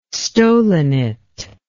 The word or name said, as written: stolonate